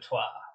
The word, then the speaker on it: trois: Twa. – What? – Dos. – That's better. – And is that other thing you said? – I said twa, like French.